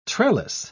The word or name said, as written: trellis